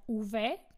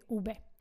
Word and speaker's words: uve: A počúvaj to, môžem povedať uve, keď som Slovenka, ale Španiel ti povie ube. Vieš prečo? Pretože oni písmenko v a b vyslovujú ústami úplne rovnako.